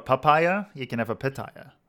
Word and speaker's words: pitaya: Pitaya. Pitaya. Pitaya. – Okay, so it's how it's spelled. Pitaya.